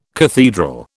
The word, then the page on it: cathedral: British English